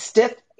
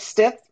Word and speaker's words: Stith: It's Stith. Yep, Stith.